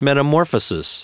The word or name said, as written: metamorphosis